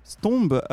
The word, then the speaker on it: Stömb: Mais avant ça, on va faire connaissance avec le groupe Stombe euh, alors j'espère déjà que je le prononce correctement.